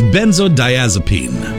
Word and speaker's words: benzodiazepine: It's benzodiazepine.